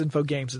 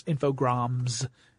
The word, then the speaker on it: Infogrames: Yeah, I keep thinking it's Infogames. It's Infogrames. Because it's spelled like Info Games, but with an extra R there, right after the G.